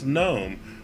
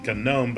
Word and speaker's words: GNOME: Desktop Linux distributions will include a windowing system like X11 or Wayland, and then there will be a desktop environment such as GNOME, or some people pronounce it GNOME because it does start with a G, or KDE Plasma.